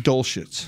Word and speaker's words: Dulcich: I know, but I think that's – I mean, Dulcich. I was trying to – Dulcich. Dulcich. – UCLA, but, right? – Yeah, UCLA tight end. – Greg Dulcich. – Dulcich.